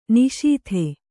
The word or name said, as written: ನಿಶೀಥ